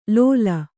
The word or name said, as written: ಲೋಲ